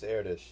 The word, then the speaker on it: Erdős: You pronounced it. – It's Erdős, yes.